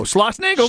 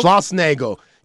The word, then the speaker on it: Schlossnagel: Jim, how do you pronounce Jim's last name? – Oh, Schlossnagel. – Schlossnagel.